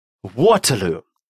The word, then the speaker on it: Waterloo: Waterloo. So har man funnit sin Överman. Waterloo. Так человек нашел более сильного противника. Строчка номер 17. Waterloo. Mäktig och väldig och stark är han. Waterloo.